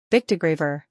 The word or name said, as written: Bictegravir